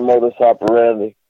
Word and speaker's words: modus operandi: The modus operandi or something? Is that how you say it? – Yeah, the modus operandi.